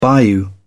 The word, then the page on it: bayou: British English